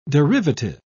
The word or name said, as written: derivative